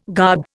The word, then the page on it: garb: Indian